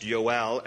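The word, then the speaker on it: Yoel (יואל): There is no J in the Hebrew alphabet. So his name in Hebrew is pronounced Yoel, and it is a compound word that means Yahweh is God.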